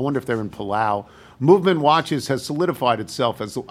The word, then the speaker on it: MVMT: Movement watches, and you'll see that it's pronounced Movement, but it's actually MVMT watches.